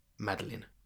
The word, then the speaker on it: Madeline: just to confirm before we carry on, how are we pronouncing her name in this podcast? Is it Madeline or Madeline? – Madeline. – Madeline. Okay, fine.